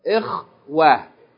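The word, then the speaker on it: إخوة: Ikhwatun. Ikhwatun. If we stop on this word, how would we pronounce it? Ikhwa.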